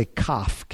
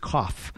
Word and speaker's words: Koph: And if it says in your Bible, it will say just above that verse, it will say Kaf, K-O-P-H, because this is the 19th letter of the Hebrew alphabet. It's the transliteral equivalent of the English letter Q. You could spell it Q-O-P-H, Kaf.